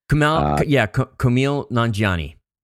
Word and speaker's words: Kumail Nanjiani: This is a film that was a Sundance hit, starring Kumail Nanjiani. I hope I'm saying his name right. – Kumail Nanjiani.